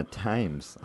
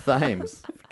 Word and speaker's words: Thames: I'm going to go drown myself in the Thames. I fucked up saying it wrong. – That's what I'm... Oh. – Thames. God damn it. – You got it half right.